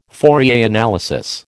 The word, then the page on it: Fourier analysis: American English